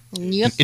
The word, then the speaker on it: Nuuk: Is it Nuuk? Nuuk? – Nuuk. – It's N-U-U-K.